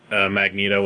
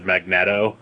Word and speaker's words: Magneto: That's like how when I was a kid I thought that Magneto was said Magneto.